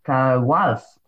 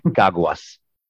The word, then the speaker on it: Caguas: Caguas, Puerto Rico. Did I totally butcher that? – A little bit, but it's fine. – No, tell me how to say it, please. – Caguas.